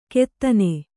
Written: ಕೆತ್ತನೆ

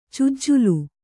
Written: ಚುಜ್ಜಲು

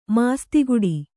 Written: ಮಾಸ್ತಿಗುಡಿ

